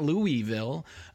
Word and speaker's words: Louisville: And by the way, Padma was spot on with the pronunciation of Louisville. It is not Louisville, everyone at home. It is not Louisville. It is Louisville. OK, my wife is from Louisville, and that is how they pronounce it there in Louisville.